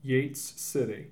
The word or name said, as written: Yates City